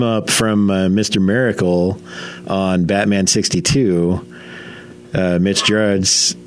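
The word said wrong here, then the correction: Gerads: Did I say it right this time? – Jared? – No. I still added the extra R.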